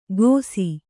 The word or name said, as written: ಗೋಸಿ